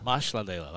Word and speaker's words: Masalela: So Masalela is the proper pronunciation. – Do that last name again. – Masalela. – Masalela. Like that's pretty good. – Like there's an S in there.